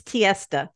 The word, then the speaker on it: Tiesta: it's it's Tiesta, like Fiesta, but with a T.